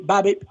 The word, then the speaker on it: BABIP: B-A-B-I-P, Babi.